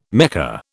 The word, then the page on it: Mecca: British English